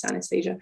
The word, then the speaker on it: synesthesia: Synesthesia.